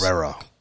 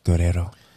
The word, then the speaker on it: Guerrero: It always sounds better if you say it like Enrique Iglesias. – Guerrero. – Guerrero.